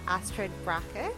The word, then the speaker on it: Astrid Bracke: But welcome to Content Etc., Astrid Bracke.